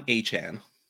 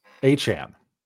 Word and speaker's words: Achane: So Achane, how did you say, how does he say it? You A-chan. Said it properly. What is it? – Devon Achane. – Achane. Okay. – He came out with a chain that says we have to say it Achane.